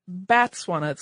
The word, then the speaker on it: Batswana: A person from Botswana is called a Motswana, and the plural of that is Batswana, so that's, I'm maybe pronouncing it incorrectly, but it's a B-A-T, not a B-O-T.